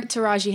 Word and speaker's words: Taraji: Taraji Henson.